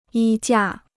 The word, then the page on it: 衣架: yī jià